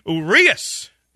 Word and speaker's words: Urias: Urias is how it's pronounced.